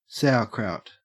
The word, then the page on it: sauerkraut: Australian English